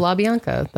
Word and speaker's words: LaBianca: So. LaBianca. That's how you say it.